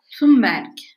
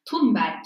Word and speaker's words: Thunberg: Thunberg. – Thunberg. Evet. Çünkü kendisi bu şekilde okuyor.